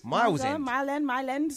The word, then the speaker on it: Mile End: Miles End. – Three. What? – Is it Miles End? – Mile End.